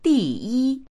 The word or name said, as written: di4yi1